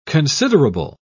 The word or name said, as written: considerable